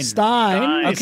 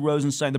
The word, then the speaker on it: Rosenstein: But the guy in charge of the Mueller investigation is Deputy Attorney General Rod Rosenstein. – Stein. I was going to say, this is going to be the podcast where we, you know, make sure people understand how these names are pronounced.